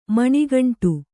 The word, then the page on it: ಮೊಣಗಂಟು: Kannada